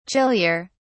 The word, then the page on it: chillier: British English